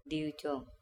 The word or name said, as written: DiucOm